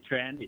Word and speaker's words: Tran: Last name is Tran, it's T-R-A-N.